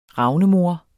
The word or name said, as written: ravnemor